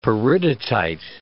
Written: peridotite

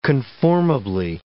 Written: conformably

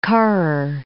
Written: Karrer

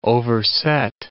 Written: overset

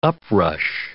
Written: uprush

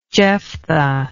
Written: jephthah

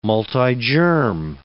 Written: multigerm

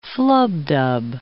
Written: flubdub

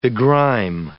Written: begrime

